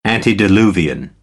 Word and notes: antediluvian: American English

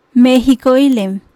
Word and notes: Meejikoilen: Maya

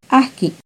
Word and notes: ahqui: Náhuatl

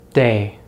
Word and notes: day: American English

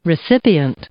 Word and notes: recipient: noun

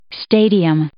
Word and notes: stadium: noun

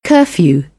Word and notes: curfew: British English; ɜː

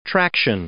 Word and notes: traction: noun

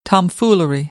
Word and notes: tomfoolery: American English; ɑː

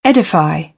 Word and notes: edify: British English; ɪ

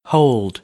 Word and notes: hold: American English; oʊ

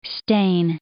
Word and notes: stain: verb

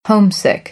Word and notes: homesick: American English; oʊ

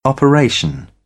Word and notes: operation: British English; ɒ

